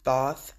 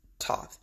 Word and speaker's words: Thoth: Katt was telling him about Joe about the uh, emerald tablets of Toth, and he kept mispronouncing, calling them Thoth, and it is pronounced or it, it like phonetically T H O T H, but it's Toth.